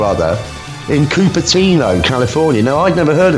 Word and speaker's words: Cupertino: Yeah, I laughed when I saw it, because even though it is pronounced Cupertino, I checked, it looks exactly like cup of tea.